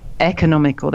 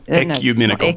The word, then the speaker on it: ecumenical: Economical. There we go. – Ecumenical.